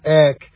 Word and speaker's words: ek: Now, there's a Greek preposition that's spelled ek and pronounced ek, and it means to take something out of.